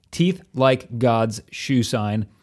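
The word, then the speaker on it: Teeth Like God's Shoeshine: Teeth Like God's Shoe Sign. Did I say it right that time? I'm not even sure. – Yes, you did.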